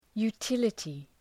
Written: utility

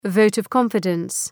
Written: vote of confidence

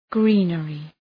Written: greenery

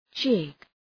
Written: jig

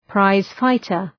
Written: prize-fighter

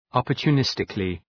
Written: opportunistically

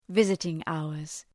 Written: visiting hours

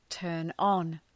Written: turn on

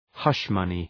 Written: hush-money

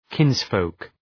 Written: kinsfolk

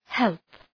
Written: help

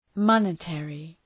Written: monetary

